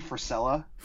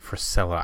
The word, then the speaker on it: Frisella: It's Andy Frisella. – Frisella, I was way off.